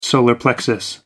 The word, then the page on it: solar plexus: American English